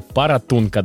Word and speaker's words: Паратунка: Паратунька и Петропавловск-Камчатский тоже отметились. Дим, я тебя немножечко поправлю. Паратунка, да, не паратунька, а паратунка.